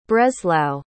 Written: breslau

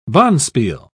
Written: bonspiel

